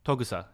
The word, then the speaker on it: Togusa: How you say it? Togusa. – Togusa. – There you go.